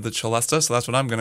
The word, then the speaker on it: celesta: In the liner notes for the album, it's just referred to as the celeste, C-E-L-E-S-T-E. Some people pronounce that celeste as well.